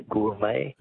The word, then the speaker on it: gourmet: Words that end with a silent T. Beret is B-E-R-E-T. Which word for a connoisseur comes from an old French word meaning wine taster? – Be a gourmet. – Gourmet, yes. Correct. G-O-U-R-M-E-T. All right.